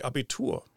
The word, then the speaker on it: Abitur: It's called the Abitur.